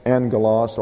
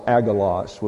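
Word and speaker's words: angelos (ἄγγελος): That's A-N-G-E-L-O-S. Now you have a double gamma here. But whenever you have a double gamma, the first gamma is translated as a new or an N. So that's how you get angel out of angelos or agalos would be the incorrect pronunciation. The first G has an N sound. So you spell it A-G-G, but when you transliterate it, it's A-N-G.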